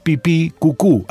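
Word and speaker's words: pipicucú: El Facebook es pipicucu, así nomás, pipí con acento en la segunda I, cucú con acento en la segunda U, pipí Los libros de Alejandro Raymond.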